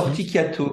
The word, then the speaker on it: Corticchiato: Comment ça se prononce pour pas faire de bêtises Corticiato, comme s'il n'y avait que des cas.